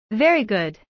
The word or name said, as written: very good